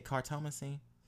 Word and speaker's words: cartomancy: So I guess to switch gears a little bit, I wanted to talk to you about your cartomancy. Is that how you say it? Cartomancy? – Cartomancy